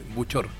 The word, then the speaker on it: Buchor: Yo quiero que me diga, Roberto, su apellido, ¿cómo se pronuncia bien? Porque no me quiero equivocar. – No, mi apellido se pronuncia súper fácil, Buchor. – Buchor. – Roberto Buchor, sí. – Ya, puedo notar porque después se me va a olvidar. – Sí, de repente es más fácil, se escribe más complejo.